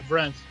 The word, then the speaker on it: Vrenth: Yeah, Vrenth.